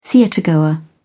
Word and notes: theatregoer: British English

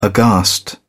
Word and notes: aghast: British English

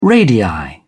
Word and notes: radii: British English

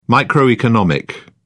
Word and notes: microeconomic: British English

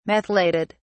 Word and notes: methylated: British English

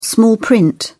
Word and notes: small print: British English

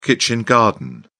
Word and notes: kitchen garden: British English

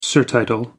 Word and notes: surtitle: American English

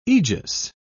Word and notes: egis: British English

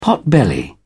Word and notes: potbelly: British English

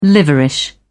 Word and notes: liverish: British English

